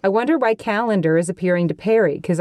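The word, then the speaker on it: Callandor: Okay, the sword is named Calendar. – Can you spell that for us? – Calendar. C-A-L-E-N-D-A-R. – Good. – Obviously. – Good. – Like a calendar.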